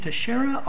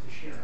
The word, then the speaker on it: Teixeira: Is it, stand up, is it the Portuguese pronunciation Teixeira or the Spanish pronunciation Teixeira? – I believe it's Teixeira. – Teixeira, so we'll go the Portuguese route.